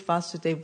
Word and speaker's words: Vasudev: Any of the ladies know who is Vasudev with the long A?